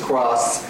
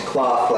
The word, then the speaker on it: cloth: You have to be careful because there's an English word CROSS, which is cross, and I a lot of times hear like CLOTH, cloth, like fabric, pronounced cross, right?